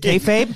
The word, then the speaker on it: kayfabe: Not breaking kayfabe. Kayfabe? Is that how you say it? – Kayfabe.